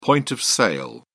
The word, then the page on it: point-of-sale: British English